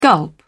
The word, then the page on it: gulp: British English